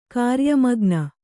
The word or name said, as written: ಕಾರ್ಯಮಗ್ನ